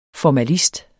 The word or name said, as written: formalist